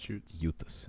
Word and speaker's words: Jutes: along with the Jutes, or some people pronounce it Jutes, but it's Jutes. It's a soft J.